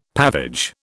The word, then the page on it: pavage: British English